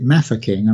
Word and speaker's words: Mafeking: Also, the other thing I'd just like to sort of point out to you is that I pronounce it Mafeking, and I think that is the proper way of pronouncing.